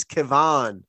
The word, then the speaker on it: Kevan: First of all, his name is spelled K-E-V-A-N. He's Kevon.